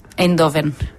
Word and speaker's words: Eindhoven: How to pronounce Eindhoven? – Eindhoven.